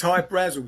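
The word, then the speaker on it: Hy-Brasil: yeah, but it's Hi Brazil because that's how the Irish would pronounce it, because it's an okay.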